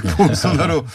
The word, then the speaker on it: 보우소나루: 근데 어 1위로 통과한 어, 대통령 후보가 어, 발음하기도 어려운데 보우소나루 어떻게 읽습니까 현재로? – 네, 자일 보우소나루입니다. 보우소나루. 네, 예, 비슷하네요. 네, 보우소나루 후보가 그냥 우파가 아니라 굉장히 극단적인 우익이에요, 그죠? – 그렇죠.